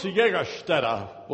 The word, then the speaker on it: Jägerstätter: Franz Jägerstätter was a rebellious youth. I took German. That's how I know how to pronounce Jägerstätter.